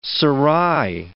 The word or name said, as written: serai